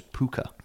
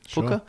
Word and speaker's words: Pwca: In its own Wales, these lights are carried by small goblin-esque fairies known as Pooka. – Oh. – Or Pooka, maybe? Pooka. Sure. It's spelled P-W-C-A, so help.